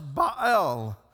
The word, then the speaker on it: Baal: properly pronounced Baal. Baal, we say it, B-A-A-L, Baal.